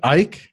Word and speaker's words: Eich: Yeah, and just to cover what we were So it's Brendan Eich, I think is how you pronounce it, or E-I-C-H? – Exactly. Yeah, okay.